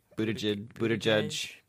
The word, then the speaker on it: Buttigieg: Pete Buttigieg, a lying motherfucker. – I like the way you say his name. Buttigieg.